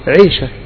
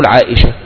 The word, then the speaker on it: عائشة: مثل مثلا كنا في اول امر، اي امرأة اسمه عائشة نقول ايه؟ عيشة، معروف كده، عيشة، روح لخالتك عيشة، عمتك عيشة. طيب لما علمنا الان الامة ان تقول عائشة، العام يقول عائشة والمتعلم يقول عائشة وهكذا.